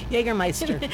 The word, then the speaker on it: Jägermeister: Jägermeister.